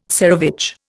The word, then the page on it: tsarevich: Indian